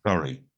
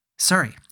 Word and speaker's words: Surrey: We talked to Elliot, who went to Duke, and he is from, I'm going to probably mispronounce this one, Surrey, England. Is that the way you say that? – Surrey. Yeah. – Surrey. Yeah. Okay.